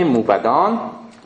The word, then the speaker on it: موبد: بپرسید مرزال را موبدی، ما تلفظ موبد نه موبد توجه کنید، چون با مثلا سپه بد و بد قافیه میشه، پس موبدی.